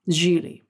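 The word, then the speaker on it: Gigli: And the premise is that Geely, Ben Affleck is Geely, pronounced like really, because it's spelled giggly. And he's trying to win over Jennifer Lopez's character.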